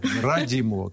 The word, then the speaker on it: Радимова: Ну, надо, можно было сакцентировать, что через О, Радимова, как футболиста, а Родимова, но это в общем... – Поэтому мне приходится уточнять, что ударение на И как раз-таки благодаря этому футболисту.